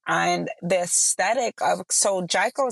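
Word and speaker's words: Jiko: There's a restaurant called Jaiko. I believe I'm saying that right. Yes. It's J-I-K-O.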